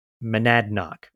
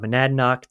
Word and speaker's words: Monadnock: Monadnock. I always say that wrong, so I have to say it slowly. – No, that's perfect. – Yeah. So there's lights from the top of Mount Monadnock.